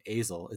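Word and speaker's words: Asle: I think you say his name Azel. It's A-S-L-E.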